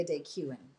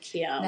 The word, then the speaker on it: Keown: Um, how do you pronounce the end of it? – Oh, it's La Galeria de Keown. De Keown. Okay, see, I would have said Cuin.